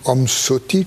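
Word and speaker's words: Om Svasti: Et il commence par un hommage en disant « Om Svati, Svasti » ou « Om Soti » comme on prononce en tibétain.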